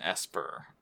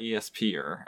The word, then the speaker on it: Esper: He's an Esper. – Yeah. – Apparently. Not an ESP-er, as I thought when I read it, but they pronounced it Esper.